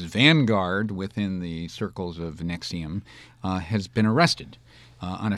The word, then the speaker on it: NXIVM: Is that how you pronounce it? Nexium? – Like the purple pill. – Right. Mm-hmm. It's spelled N-X-I-V-M. Nexium.